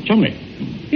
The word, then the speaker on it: Cholmondeley: No, it's only spelt that way, my dear fellow. You see, it's pronounced Cholmondeley.